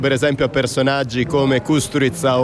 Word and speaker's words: Kusturica: perciò Kusturica, o Kusturica come si pronuncia in realtà,